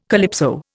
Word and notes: calypso: Indian

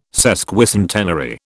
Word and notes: sesquicentenary: British English

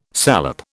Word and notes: salep: British English

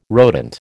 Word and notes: rodent: American English

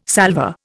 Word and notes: salvor: Indian

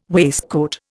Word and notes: waistcoat: Indian

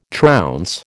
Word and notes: trounce: American English

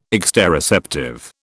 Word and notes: exteroceptive: British English

